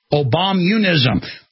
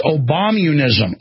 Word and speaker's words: Obamunism: Obamianism. How do you say it? Obamianism? Obamianism. Obamianism. No, that's not right. Obamunism. Sometimes I have trouble with a word. It's Obamunism.